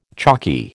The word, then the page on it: chalky: American English